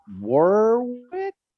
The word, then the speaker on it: Warwick: We have Mike and Travis of, help me out, guys, Drownlands up in Warwick. Did I say it right? – Yep.